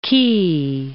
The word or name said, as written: quay